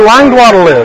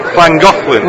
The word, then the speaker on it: Llangollen: Do you know where Langwaddle is? – Llangollen.